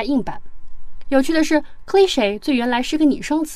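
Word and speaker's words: cliché: cliche 这个单词的读音呢，在英式读音当中是重音在前，念作 cliche，美式读音的重音在后，念作 cliche。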